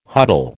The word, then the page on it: huddle: American English